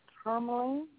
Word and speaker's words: tourmaline: So okay, with the black tourmaline, that's how you pronounce it.